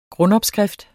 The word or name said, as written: grundopskrift